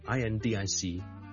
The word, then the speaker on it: indicative: 但如果將佢轉咗做 adjective 嘅時候呢嗰、那個重音呢係將佢轉移到去 dic 度就變咗呢 indicative 呢就係 indicative 就唔係 indicative OK indicative